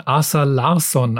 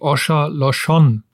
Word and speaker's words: Åsa Larsson: Ich habe einen von Asa Larsson aus Schweden und sie hat mir mal gesagt, sie wird gar nicht so ausgesprochen. Eigentlich heißt sie Osha Loshon, aber sie hat gemeint, es ist ganz okay, wenn wir Deutsche das so sagen.